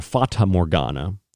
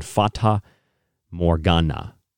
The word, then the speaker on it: Fata Morgana: Now, Fata Morgana, or a Fata Morgana, I believe is the correct pronunciation, Fata Morgana, is likely the scientific, if you will, term.